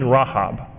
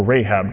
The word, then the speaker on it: Rahab: However, in Jericho, if we remember the story, there was a woman named Rahab, or Rahab,